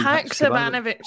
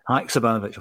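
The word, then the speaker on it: Hakšabanović: Haksabanovic. – Haksabanovic.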